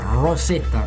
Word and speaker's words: Rosetta: Rosetta, detto in con la pronuncia inglese.